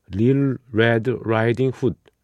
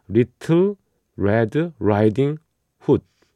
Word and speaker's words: Little Red Riding Hood: Lil Red Riding Hood. 예, 정확하게 말을 발음을 해드린다면 Little Red Riding Hood 이거죠.